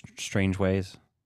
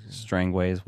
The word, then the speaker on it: Strangways: and then you're introduced to strange ways. – Strangways, whatever they call them. I just feel like it should be pronounced Strangways because it's cooler that way.